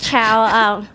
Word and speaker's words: Cao: Yes, it is Chow. Oi has been. I didn't change it for my new job, but it's spelled C-A-O, which is the Mandarin spelling of the Chow, C-H-O-W. So it could have been C-H-O-W, then it would have been...